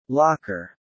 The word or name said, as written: locker